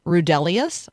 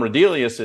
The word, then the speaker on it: Rudelius: And so could you introduce us to Tom Rudelius, I'm going to guess, is maybe how you say his name. – Yeah, well, congratulations on saying his name correctly, because it's not obvious.